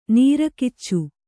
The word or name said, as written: ನೀರಕಿಚ್ಚು